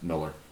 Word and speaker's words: Millar: Which is spelled Millar, but pronounced Miller.